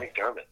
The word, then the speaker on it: McDermott: I said that correctly, did I? Your last name? – You did say that correctly. Right. Yes, Micheal McDermott.